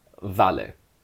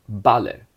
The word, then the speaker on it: vale: In the languages that I speak, it's given usually by the letter V, it's given by the letter W in German, but in Spanish it's pronounced exactly the same as the letter B. These words are not pronounced vale, vamos, vuelta, but vale, vamos, vuelta.